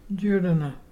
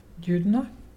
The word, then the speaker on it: Jurruna: Judana. Judana is pelican. And to spell it is J-U-R-R-U-N-A. And you say Judana?